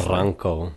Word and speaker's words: Barranco: And I can't remember the name because I just can't pronounce it nicely. – Barranco. – Barranco. See, I can't. – B-A-R-R-A-N-C-O. – Okay. – Barranco.